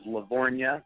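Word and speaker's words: Lavorgna: And so in high school, people called me Tony Tone, and my real last name is Lavornia, and since it's a silent G, people always go Lavagagna, Lavagorga.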